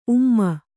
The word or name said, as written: ಊಮ